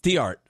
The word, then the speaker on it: Theart: Theart.